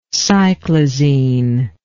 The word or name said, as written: cyclizine